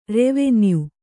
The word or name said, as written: ರೆವಿನ್ಯೂ